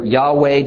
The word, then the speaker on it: Yahweh: Yehovah or Yahweh, depending on which Hebrew scholar you believe is correct as to this name's pronunciation, is the completely unique word that God says is his personal name.